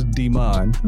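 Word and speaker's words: De'Mon: So my middle name is Demon.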